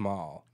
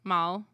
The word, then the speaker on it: Malle: I think it's just Malle. – Malle? – Yeah. – Louis Malle. That makes sense. – Yeah. – Because French people don't pronounce half the letters.